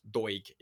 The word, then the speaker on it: Doig: Doig from Doig, even. Not a soft G, it's a hard G from Hibs as well.